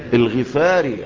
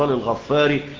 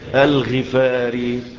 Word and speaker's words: الغفاري: لأنه بينسب إلى قبيلة غفار مش غفار، يبقى القبيلة اسمها إيه؟ غفار. لو القبيلة اسمها غفار يبقى أقول الغفاري، لكن القبيلة اسمها إيه؟ غفار. ولذلك يقال أبو ذر إيه؟ الغفاري، عشان تنطقها صح، عشان تنطقها صح، ما تقعدش في مجلس تقول أبو ذر الغفاري يقول لك مين اللي بيعلمك، يقول له بصراحة الله، يقول لك طب خليه يعلمك علم، لا، أبو ذر الإيه؟ الغفاري.